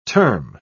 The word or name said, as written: term